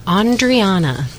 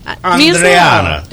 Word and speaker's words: Andreana: Well, I'm Italian, so it's Andreana.